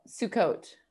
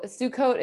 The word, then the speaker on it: Sukkot: Wait, I want to see how to pronounce it, but let's hear it. Did you hear that? – No. – Oh, Sukkot. So Sukkot is a holiday, Sukkot, which it's a holiday that you'll see being celebrated like in Hasidic neighborhoods in New York.